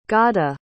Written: ghada

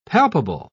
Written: palpable

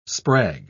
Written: sprag

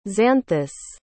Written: xanthus

